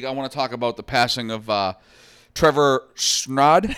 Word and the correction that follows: Strnad: I don't know how to pronounce it. I'm sorry. It's Strnad. He was the lead vocalist, singer, growlist for the death metal band Black Dahlia Murder out of Michigan.